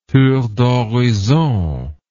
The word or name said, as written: tour d'horizon